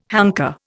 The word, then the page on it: hanker: Indian